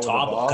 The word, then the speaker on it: Catawba: But today I'm drinking a beer called Brown Ale from Catawba. – There we go, like Chumbawamba. – It's spelled Catawba. – Or ball Catawba, with a ball.